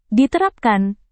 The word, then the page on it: diterapkan: Indonesian